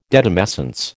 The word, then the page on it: detumescence: American English